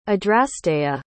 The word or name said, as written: adrasteia